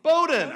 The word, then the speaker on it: Bowdoin: Bowdoin.